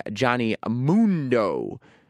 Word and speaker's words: Johnny Mundo: Taya and Johnny Mundo. That's how you pronounce it.